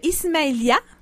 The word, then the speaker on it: Ismailia: Abachi est arrivé à Drummondville il y a plus de 25 ans, originaire de l'Égypte, de Ismailia, si je prononce bien. – C'est bien ça, oui.